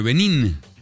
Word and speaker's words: Benín: Benín, ¿cómo ahí se escribe? Bien. – B-E-N-I-N. – Y Togo. – B-E-N-I-N, Benín.